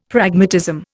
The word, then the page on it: Pragmatism: Indian